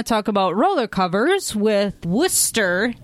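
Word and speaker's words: Wooster: Yes, that is the correct pronunciation. – Even though it's spelled wrong, W-O-O-S-T-E-R. I have called it Worcester. – A lot of people do. – For 20 years. – Like rooster.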